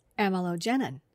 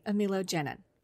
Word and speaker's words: amylogenin: So now I pronounce it correctly, amylogenin.